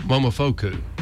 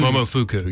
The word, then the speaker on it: Momofuku: Oh, Momofuku. Or Fuku. – That was the best food. – Momofuku, yeah.